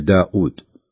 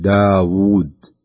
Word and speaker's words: داود: كذلك في قوله تعالى واذكر عبدنا داود، وهذه حقيقة نسمعها من بعض الناس يقرأها بهذا الشكل داود، فيهمز الواو يهمزها عند نطق الناس لهذا الاسم داود، يعني غالبا بعض الناس ينادي أهله داود أو كذا وأخوانه أو زملاه داود، ولكن في القرآن لا، في القرآن تقرأ بهذا الشكل داود واوين بهذا الشكل، تكون واوين يعني خلف بعض داود داود، تكون مدية مدا طبيعيا.